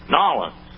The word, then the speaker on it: New Orleans: How about New Orleans? Nawlins, to be more correct in the local pronunciation.